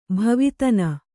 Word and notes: ಭವಿತನ: bhavitana

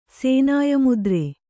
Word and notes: ಸೇನಾಯಮುದ್ರೆ: sēnāya mudre

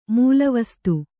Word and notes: ಮೂಲವಸ್ತು: mūla vastu

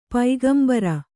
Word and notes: ಪೈಗಂಬರ: kannada

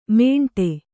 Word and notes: ಮೇಂಟೆ: mēṇṭe

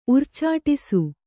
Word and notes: ಉರ್ಚಾಟಿಸು: urcāṭisu

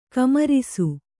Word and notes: ಕುಮ್ಮರಿಸು: kummarisu

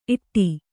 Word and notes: ಈಡ: Kannada; īḍa

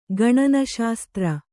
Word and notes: ಗಣನಶಾಸ್ತ್ರ: gaṇa śastra

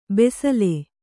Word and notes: ಬೆಸಲೆ: besale

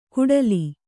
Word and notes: ಕುಡಲಿ: kuḍali

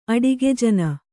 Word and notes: ಅಡಿಗೆಜನ: Kannada

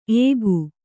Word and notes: ಏಕು: kannada

